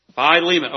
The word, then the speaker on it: Philemon: In verse 9, Onesimus, the reason for the letter, and I can never say, Philemon, is that how you say that? Philemon, okay, one of the syllables were wrong.